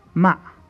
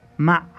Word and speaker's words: مع: مع. مع. Try to pronounce this a please from the bottom of your throat.